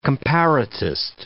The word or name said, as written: comparatist